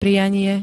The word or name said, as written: prianie